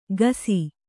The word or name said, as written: ಘಾಸಿ